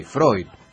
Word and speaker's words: Freud: El diptongo EU en alemán, y el idioma madre, es hoy, Freud. Por eso Freud. – Litongo. – El diptongo EU de Freud, como dice usted, es, EU. – Se pronuncia hoy.